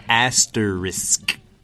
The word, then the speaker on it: asterisk: Yeah, asterisk. – Asterisk. – Yeah, asterisk.、大家可以把它分成两部分、Ast, 然后 risk.、Like, you know, it's very risky.